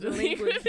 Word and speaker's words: DLEQUINT: It's pronounced delinquent, bitch.